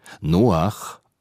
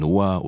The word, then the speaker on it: Noach: Noach, so die korrekte hebräische Aussprache, aber meist nennt man ihn Noah oder Noe, dieser Name Noach ist nicht eindeutig zu übersetzen.